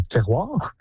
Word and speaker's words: terroir: And a sense of, I don't know how you say this in English, but terroir.